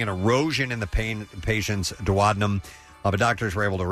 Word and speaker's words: duodenum: A duodenum.